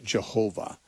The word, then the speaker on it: Jehovah: and pronounce it Jehovah with a J sound at the beginning.